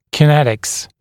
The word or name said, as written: kinetics